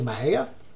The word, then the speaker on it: Mexia: it's not spelled how it sounds. Mejia is actually M-E-X-I-A. Most folks think it's pronounced Mexia. It's actually Mejia.